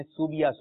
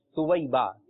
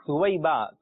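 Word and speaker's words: ثویبہ: اچھا اس نام کا جو تلفظ جو ہے لوگ بگاڑ دیتے ہیں اور کہتے ہیں سوبیہ سوبیہ, یہ زیادہ بولا جاتا ہے, تو اس کا درست تلفظ ہے سویبہ. کیا ہے؟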